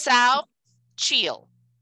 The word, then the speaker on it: Xochitl: It's spelled X-O-C-H-I-T-L. Mm-hmm. So I would say Xochitl. That's how I would pronounce it. But it's Sao Chiel.